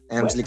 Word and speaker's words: Elmsley: ¿Así se pronuncia? Sí, Elmsley Count, ajá.